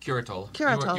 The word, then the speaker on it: Curatola: I didn't say that right, did I? Curatola? – Curatola.